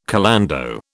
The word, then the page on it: calando: British English